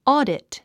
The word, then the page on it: audit: ɔ́ːdit; オゥディット